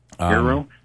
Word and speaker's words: Eero: Right now, we tend to like the Eero the best. – Eero? How do you spell that? E-E-R-O.